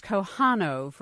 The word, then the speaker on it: Kohanov: And to get your name right, it's Kohanov, right? – Yes, that's okay.